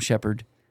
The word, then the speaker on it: Shepherd: My name is spelled T-H-O-M-S-H-E-P-H-E-R-D, Tom Shepherd. No A's in it.